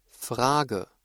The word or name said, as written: Frage